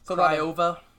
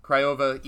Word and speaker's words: Craiova: Hapoel Beersheva and University Tatai, I probably butchered that. – Yeah, Craiova. – Yeah, Craiova, easier to say.